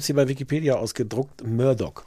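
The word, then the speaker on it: Murdaugh: Sagt man Murdoch oder Murdoch? Alle sagen Doc, aber ich habe das Gefühl, es müsste eigentlich. – Alle sagen, dass es Murdoch ausgesprochen wird, obwohl es sich wirklich eigentlich nicht so schreibt.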